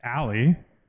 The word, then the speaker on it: Ali: His first name is pronounced Ali. – That's right. – Not Ali. – Oh, really? – Ali. – Oh, it's Ali.